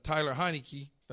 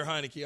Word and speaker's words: Heinicke: Taylor Heinicke. Did I say it right? – Yeah. – Taylor Heinicke.